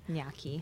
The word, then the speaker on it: gnocchi: Gnocchi. – I say gnocchi, but I'm not Italian, so I could be saying it wrong. – I think you're saying it right, though. Gnocchi.